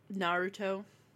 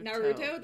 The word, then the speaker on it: Naruto: You say it like a Midwestern mom every time. Naruto. – Naruto? Naruto. – Naruto. – Naruto, that's correct. – Naruto. You're rushing through the Naruto.